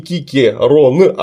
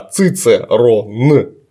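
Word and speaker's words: Cicero: Вот далее, почему он не Кикерон, а Цицерон? Сразу говорю, буква С, русская С, в латинском языке читается только как К.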